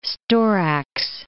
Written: storax

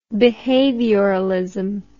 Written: behavioralism

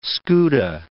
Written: scuta

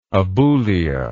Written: aboulic

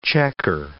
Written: checker

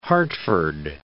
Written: Hartford

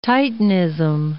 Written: titanism

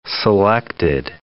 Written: selected

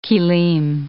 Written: kilim

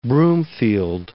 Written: Broomfield

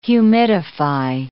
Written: humidify

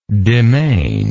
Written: demesnial

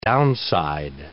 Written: downside

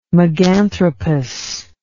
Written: meganthropus

